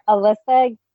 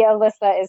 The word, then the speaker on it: Elisa: And it's under Alyssa Gabrielle Donahue, just a little product placement. And the Alyssa is spelled E-L-I-S-A, not the normal assumption.